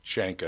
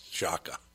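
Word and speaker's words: Chaka: And then I tell my wife yesterday, it's not Shanka, it's Shaka. But I said, you've heard of Shaka Khan. It's just that's spelled with a C.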